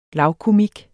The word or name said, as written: lavkomik